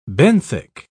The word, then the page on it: benthic: British English